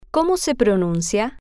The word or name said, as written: cómo se pronuncia